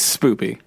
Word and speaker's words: spoopy: Spooky or spoopy? Because it sounds spoopy. Like you're saying spoopy. – It is spoopy. Because it's not spooky.